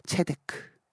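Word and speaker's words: 체데크 (צדק): 행하시나이다 이렇게 말씀하시는 것처럼 여러분 공의란 히브리어로 체데크입니다. 같이 한번 발음해 볼까요? 체데크.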